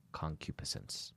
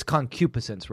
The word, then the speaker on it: concupiscence: A good segue, and I'll allow it, because we're talking about lust and concupiscence. – Yes. – It's difficult to pronounce. I'm pretty sure that the accent is on the antepenult of that word, but I'm sorry if I'm wrong.